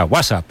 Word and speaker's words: WhatsApp: No, peor aún, con acento en la primera, WhatsApp, ¿no?